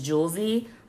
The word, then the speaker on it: Jouelzy: And someone who I've been following, I've been kind of following their journey, following their content, is Jewelzy. Her name, or I'll spell it for you, J-O-U-E-L-Z-Y.